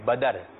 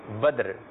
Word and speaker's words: بدر: کچھ لوگ اس نام کو کہتے ہیں بدر، تو بدر جو ہے وہ درست نہیں ہے، درست تلفظ ہے اس کا بدر.